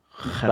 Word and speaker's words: Gerrit: Well, my name is spelled with a G, G-E-R-R-I-T, yeah. And English is my second language. – Yeah. – In Afrikaans, G is pronounced as a G. So Gerrit. Back home, they would pronounce it as Gerrit.